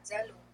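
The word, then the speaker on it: zaalouk: And Zaluk, let's hear how they pronounce Zaluk. Zaluk. Attends. Attends, s'il vous plaît. Zalouk. Zaluk. And Zalouk is a Moroccan salad, eggplants, tomatoes with garlic cumin, paprika.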